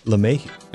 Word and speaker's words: LeMahieu: As well as DJ LeMahieu, by the way, who's DJ LeMahieu. – That's right.